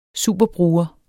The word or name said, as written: superbruger